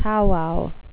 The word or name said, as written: powwow